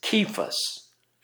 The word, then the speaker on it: Cephas: And when James, and we usually pronounce this Cephas, but I think it comes from the Chaldean. It's a Hebrew word. I think it's Cephas, actually, with a hard K.